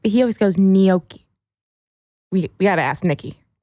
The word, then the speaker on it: gnocchi: How do you say gnocchi? – Gnocchi. – Because the waiter always goes, how does he say it? – I would have said ganache. – Gnocchi.